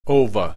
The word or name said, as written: ova